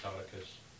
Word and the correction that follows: caduceus: But Caduceus.